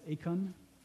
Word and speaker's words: eikon: That's a Greek word. It's pronounced eikon.